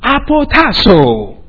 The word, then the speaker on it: apotasso: Now the expression give up, actually, is translated from a Greek word, apotasso.